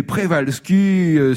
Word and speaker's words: Przewalski: Alors ça s'écrit Przewalski, mais Prévalski, c'était la bonne prononciation.